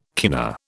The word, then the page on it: kina: British English